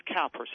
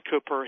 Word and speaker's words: Cowper: Yes, you would think that it would be pronounced Cowper, C-O-W-P-E-R, but yeah. It's pronounced Cooper.